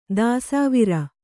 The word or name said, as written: ದೆಸೆವರಿ